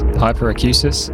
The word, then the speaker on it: hyperacusis: You can develop something called hyperacusis. I think I've pronounced that correctly. Let me check. Yes, hyperacusis,